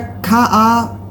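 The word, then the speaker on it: Kafvka: Du nimmst Alle hassen Nazis von, ich weiß nicht, wie ich es richtig ausspreche, ist es Kafka oder Kafka. – Aber mit V da in der Mitte, ne? – Genau.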